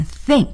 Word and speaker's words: think: 再来学习另外一个动词，如果我们说我想怎么怎么样这个我认为怎么怎么样，这时候呢要用 think, T-H-I-N-K, think. 啊，注意，这里又涉及到 th 的发音，我们一定要把这个舌尖啊伸在啊上牙齿和下牙齿中间进行摩擦。